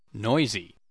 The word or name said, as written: noisy